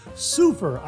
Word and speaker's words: Seufer: Viewer David Seufer, I think that's how he pronounces his last name, S-E-U-F-E-R.